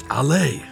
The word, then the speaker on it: Allée: It's pronounced Allée.